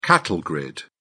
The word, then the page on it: cattle grid: British English